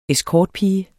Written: escortpige

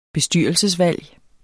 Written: bestyrelsesvalg

